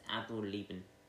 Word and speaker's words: Avril Lavigne: It's Avril Lavigne.